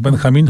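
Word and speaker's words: Benjamín: Benjamin, chociaż on najnowszą swoją książkę napisał po angielsku, no ale wymawiamy go z hiszpańska rzecz jasna. Benjamin.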